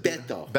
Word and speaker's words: Beto: Beto.